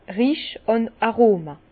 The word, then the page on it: Rich àn Aroma: Bas-Rhin; Herrlisheim